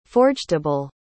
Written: forgetable

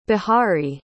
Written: Bihari

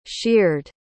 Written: sheared

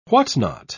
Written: whatnot

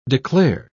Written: declare

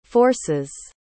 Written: forces

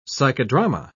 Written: psychodrama

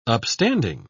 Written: upstanding

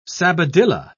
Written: sabadilla

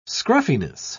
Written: scruffiness

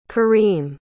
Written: kareem

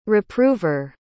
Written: reprover

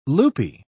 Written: loopy

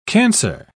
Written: cancer

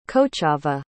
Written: cochava

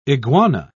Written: iguana

